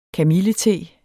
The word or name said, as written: kamillete